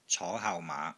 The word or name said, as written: CO5 HAU6 MAA5